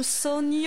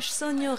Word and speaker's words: Sonho: Flavia, donc on rappelle l'album Sonho. – Sonho. – Je pense que ça se prononce comme ça, hein, le NH Sonho Real, qui est sorti en 2016.